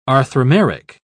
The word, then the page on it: arthromeric: British English